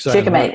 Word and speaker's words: shikimate: Shikimate, S-H-I-K-I-M-A-T-E, shikimate.